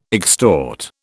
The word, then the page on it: extort: British English